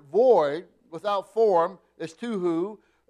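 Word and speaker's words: tohu: It's a Hebrew word that's pronounced tuhu.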